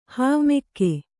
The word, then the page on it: ಹಾವುಮೆಕ್ಕೆ: Kannada